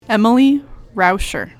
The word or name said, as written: Emlly Rauscher